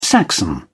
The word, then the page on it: saxon: British English